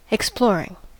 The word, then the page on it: exploring: American English